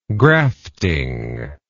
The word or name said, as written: grafting